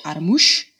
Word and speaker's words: Adamus: It's spelled Adamus, but they pronounce it Adamush.